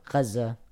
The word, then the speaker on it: Gaza: So you have a small embankment right about here called, I'll pronounce it in English, Gaza. – How do you pronounce it for real? – Gaza. – Okay. Let me see if I can say that. Gaza. – Gaza. – Gaza. No, I'm doing the kh too much, right? – Gaza.